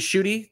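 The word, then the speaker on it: Ncuti: You pronounce it as shooty.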